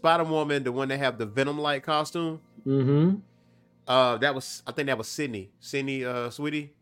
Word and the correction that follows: Sydney Sweeney: If I'm saying the name right, Sydney Sweeney.